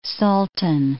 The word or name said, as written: Salton Sea